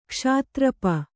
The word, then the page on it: ಕ್ಷಾತ್ರಪ: kṣātra pa